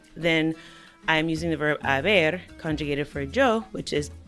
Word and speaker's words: haber: However, as you know, in Spanish, the H is not pronounced. It is silent unless the letter C appears before it. So haber is actually spelled H-A-B-E-R.